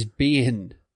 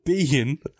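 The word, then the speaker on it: Biehn: B-E-I-H-N is Bean. – Oh, sorry. Michael Bean. – Bean? – Bean. Bean is great here.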